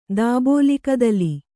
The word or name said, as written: ದಾಬೋಲಿಕದಲಿ